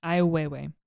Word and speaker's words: Ai Weiwei: Anyone with a bit more art knowledge might also consider Anselm Kiefer's sunflowers prints, or I do not know how to pronounce this guy's name. Ai Weiwei? Sunflower seeds? – Weiwei, I believe. – Weiwei? Ai Weiwei.